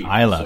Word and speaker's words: Islay: Islay, so yeah, I've been pronouncing it right.